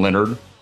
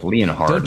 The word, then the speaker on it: Leonard: How do you say his name? He's one of my favorite coaches. Jim Leonard. – Dude, stop. – It's spelled like Leon Hard.